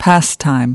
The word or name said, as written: pastime